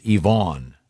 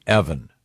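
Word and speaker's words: Evon: It's E as in E, Von like Vaughn. So it's Yvonne, not Evan.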